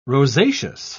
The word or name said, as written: rosaceous